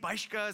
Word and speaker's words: Bieschke: So Bieschke is a German name, Bieschke is how it's pronounced.